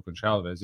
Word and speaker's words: Gonçalves: Or it is a soft C. It's not a hard C, I'm pretty sure, in Portuguese. So Pedro Gonçalves, yeah.